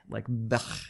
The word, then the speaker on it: Beche: But I'm asking how do you pronounce B-E-C-H-E? – B-E-C. – Beach? Bechhe? Bechhe? – Bech. Maybe it's Beck. – Bech. – Yeah. – Like Bech. – Yes. – Like that? Okay, good.